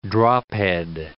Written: drophead

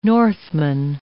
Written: Northman

